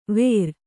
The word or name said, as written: ವೇರ್